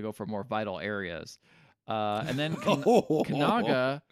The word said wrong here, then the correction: Kananga: takes Bond's watch and then says, okay, on the back of the watch, it says Kananga. – Okay. – We've been saying it wrong this entire fucking time, I think.